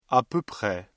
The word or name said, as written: à peu près